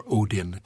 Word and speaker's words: Odin: Well, the three most important gods were Odin, who was the king of the gods, so-called, or Odin, depending on how you pronounce it, Thor and Freyr.